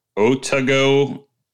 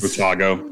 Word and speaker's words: Otago: Otago is Otago.